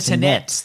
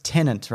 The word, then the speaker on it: Tenet: They should be called the Tenets, like the Rockets. – I know. People say it like it's Tenant, right? – Like a tenant. – Yes. – Yes. – But it's not spelt like that. If it was tenant, it would be T-E-N-E-N-T.